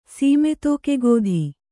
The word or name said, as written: ಸೀಮೆತೋಕೆಗೋಧಿ